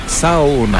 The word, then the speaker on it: Saona: En fait, on prononce chaque lettre, donc c'est Saona. – Ah oui, Saona, c'est vrai qu'on prononce chaque lettre. C'est facile de ce côté-là pour la prononciation. – Eh oui. – Donc on retient qu'il suffit de prononcer chacune des lettres.